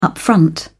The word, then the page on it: upfront: British English